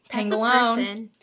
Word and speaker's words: pangolin: How do you pronounce it? I don't even know if I'm pronouncing it right. – It's a pangolin. – Is that it? – Yeah, that's it.